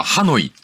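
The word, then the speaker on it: Hà Nội: 河内でいいんですか、それを、ベトナムの音ではハノイって読むんだって。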